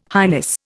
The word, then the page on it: highness: Indian